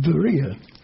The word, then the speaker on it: Berea: Just as a sideline and out of interest, you might have noticed that I pronounced the word Berea, Berea, as if it was a V. It is probable that even in those days the Greeks pronounced their B's as V's. They certainly do now. It's even spelled V-E-R-I-A if you went there today.